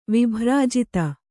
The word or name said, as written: ವಿಭ್ರಾಜಿತ